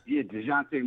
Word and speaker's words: Dejounte: Dejounte Murray. Yeah, Dejounte Bart. And, yes, I know that there are, people with significant voices with the Knicks that really like the idea of acquiring Dejounte.